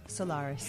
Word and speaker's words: Solaris: So it's a short A, not a long A, the way Ellen was pronouncing it. – Solaris.